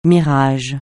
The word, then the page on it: mirage: francais